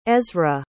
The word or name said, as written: ezrah